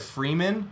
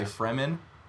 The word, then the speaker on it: Fremen: You know, like I always said Freeman when I read it, but I think a lot of people say Fremen.